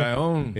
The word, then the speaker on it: El Cajon: We were talking about the border, and the mayor of El Cajon, I guess it's pronounced El Cajon, California. – El Cajon.